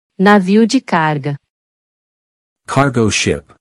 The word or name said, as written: Navio de carga